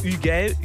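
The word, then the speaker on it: Hugel: Und der Gast heute, also der erste, ist Ugel, französisch ausgesprochen, der Typ, der Bella Ciao geremixt hat.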